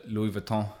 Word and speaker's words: Louis Vuitton: but he had a couple of Louis Vuitton. That's how you say it in France.